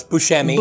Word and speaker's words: Buscemi: No, it's Buscemi. Buscemi. Uh, Buscemi. Buscemi. – There we go.